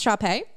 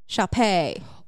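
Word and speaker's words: Shar Pei: Is that how it's pronounced, Sharpay? – I think so. It's two words. – Yep. – S-H-A-R. – Mm-hmm. – Space. P-E-I. – Sharpay.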